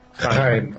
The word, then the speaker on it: Chaim: Is it Shame or Shame? – Chaim. – How do you say it? – Chaim.